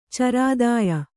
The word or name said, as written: ಚರಾದಾಯ